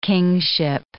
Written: kingship

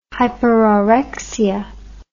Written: hyperorexia

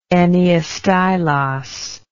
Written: enneastylos

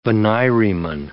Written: venireman

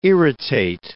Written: irritate

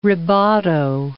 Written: rebato

